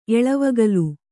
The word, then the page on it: ಎಳೆವಗಲು: Kannada